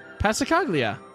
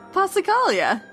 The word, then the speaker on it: Passacaglia: That's how you want to say that word? – Handel? – No, Passacaglia. – Passacaglia. – That's not how you say it. – How do you say it? – Passacaglia. – There's no G? – No.